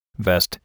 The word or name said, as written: vest